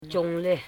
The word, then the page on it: chongle: chong.le